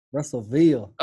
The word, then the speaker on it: Russellville: Did I say that right? Is it Russellville or Russell? – Russellville. – Okay, Russellville. That's what I thought.